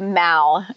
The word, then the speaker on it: Mall: It's spelled Mall, M-A-L-L, but it's the Mall.